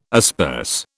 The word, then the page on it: asperse: British English